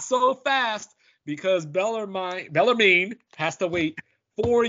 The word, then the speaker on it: Bellarmine: I want to say Bellarmine, but I put pronounce it to Google and it told me Bellarmine down in Louisville, Kentucky.